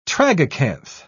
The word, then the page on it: tragacanth: British English